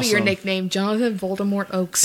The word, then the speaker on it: Voldemort: I read the other day that J.K. Rowling said the correct pronunciation Voldemort. – Is Voldemort. – Yeah. – Mm-hmm. – Mind blown. – How do you make the T silent is my question.